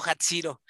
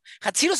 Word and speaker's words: Jatziro: Una persona con mucho contenido, con mucha experiencia de vida y la verdad es que es, es un gustazo, Hatsiro. ¿Hatsiro se escribe con Z o con S? – J-A-T-Z-I-R-O.